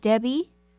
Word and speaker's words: Debby: Just write to Ask Coach Debbie. My name is spelled D-E-B-B-Y.